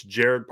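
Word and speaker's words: Gerad: It's Jared Parker is how you say it. It's G-E-R-A-D. So I didn't know if it was, okay, is it Jared? Is it Gerard? I thought it was Gerard at first.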